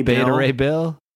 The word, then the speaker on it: Beta Ray Bill: The word's definitely beta. Come on. Beta male. – Beta Ray Bill.